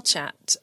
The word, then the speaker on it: Chaat: I have been loving listening to Girl Chat with Uma Nayar. And it's Girl Chat, Girl Chat, like it's spelled C-H-A-A-T, because it's like the chat and the bread.